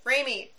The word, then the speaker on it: Raimi: Raimi.